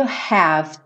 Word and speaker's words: have: More than words is all you have to do. Have to do. Esse é, não vai ser pronunciado, né? Então, pensa no V, ó.